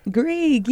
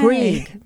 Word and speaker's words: Grieg: Grieg? – Grieg, yeah. Grieg. Norwegian name there.